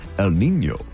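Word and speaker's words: El Niño: You said that wrong. – Oh, I'm sorry. You're right. Yes, you're right. Whenever we talk about it, we have to say it like this. El Nino.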